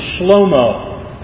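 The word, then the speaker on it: Shlomo (שלמה): He who brings peace is actually a pronunciation of Solomon's name in Hebrew is Shlomo.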